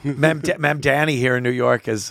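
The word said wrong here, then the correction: Mamdani: Mem Danny? Who says his name like that? – Mem Danny? How do you say it? – Mumdani.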